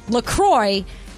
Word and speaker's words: La Croix: And they even say on their website, it's definitely pronounced La Croix. And if you ever get confused about it, just remember it rhymes with enjoy.